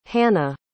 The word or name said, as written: hanna